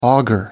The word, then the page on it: auger: American English